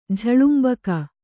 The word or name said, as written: ಝಳುಂಬಕ